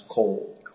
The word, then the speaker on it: Colle: The last thing is, there was, you heard me ask a lot of questions about the case I'll pronounce, Cole. – Yes. – C-O-L-L-E.